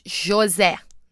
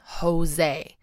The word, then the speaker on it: José: And yes, while we're at it, it's José, not Jose. He's Brazilian, and we pronounce it with the ch.